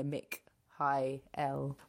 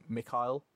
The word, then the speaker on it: Mikhail: So I think they're really like defining the Mick high L. – I think it's because pe- a lot of people maybe do the two syllable Mikhail.